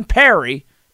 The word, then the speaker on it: Parry: Well, John Perry is one of those guys. – Mm-hmm. – He's retired now. And you remember it because it's spelled P-A-R-R-Y when you would see it, right?